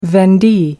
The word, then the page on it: vendee: American English